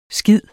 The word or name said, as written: skide